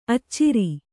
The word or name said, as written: ಅಚ್ಚರಿ